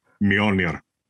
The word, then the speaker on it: Mjolnir: God, Mjolnir.